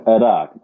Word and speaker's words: Iraq: Well, I was saying Iraq. That is the Arabian pronunciation. And because Arabic is my first language, I'm so used to saying things the Arabic way.